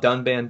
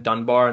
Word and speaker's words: Dunban: But they do mispronounce, like, they call Dunban Dunbar, and they call Ryan, like, Rain, and people are not happy about that either.